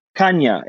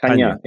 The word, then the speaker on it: Chania: You mentioned Crete, and I don't know how to pronounce the name. Chania? It's on the north side of the island. I think it's spelled Chanai. – Oh, Chania. Exactly. Chania. Exactly.